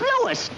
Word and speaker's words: Louis: Sounds like there might be a story at the plant, Lois. – Lois? Me name is Lewis, not Lois. Chief Wiz, everybody in Toypel me name wrong. It's Lewis. L-O-U-I-S.